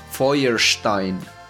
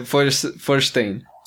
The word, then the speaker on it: Feuerstein: Bueno, para todos quienes no sepan alemán, yo les digo que el apellido se dice Feuerstein. Feuerstein. Yo dudo mucho que alguien, haya alguien que no sea alemán que lo pronuncie así y por eso él dice, él mismo no lo pronuncia como a la manera correcta alemán, ¿no? Él dice Forstein.